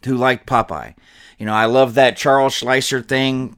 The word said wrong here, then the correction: Fleischer: Charles Fleischer, I think it's Fleischer.